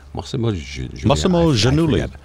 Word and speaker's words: Massimo Giannulli: Massimo Giannulli? – Massimo Giannulli.